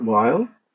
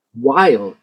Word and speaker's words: Weill: I would venture to say Kurt Weill. He won. He wanted to be American. He wanted his name pronounced Weil and not Weil when he came here.